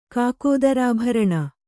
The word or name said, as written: ಕಾಕೋದರಾಭರಣ